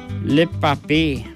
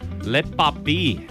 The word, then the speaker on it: le papier: Le papier. – Le papier.